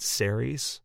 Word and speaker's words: Ceres: And Ceres, I think that's how you say it, right? Ceres? – Yeah, that sounds about right.